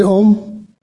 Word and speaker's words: Om: Just become Om. That's how it sounds when you say it, Om. And the same in Hindi language, that also they say Om. In yoga, they say Om.